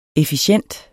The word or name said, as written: efficient